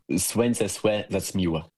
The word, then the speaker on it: Słońce se zaćmiło: Płyty z waszym udziałem, ponieważ w kwietniu tego roku, prawda, ukazała się wasza płyta pasyjna Słońce se zaćmiło. Dobrze to wymawiam? – Tak. Słońce se zaćmiło. Ale to jest język kaszebski.